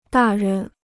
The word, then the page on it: 大人: dà ren